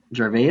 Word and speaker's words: Gervais: Well, if you go back to, like, whenever Ricky Gervais, is that how you pronounce his last name? – Yeah.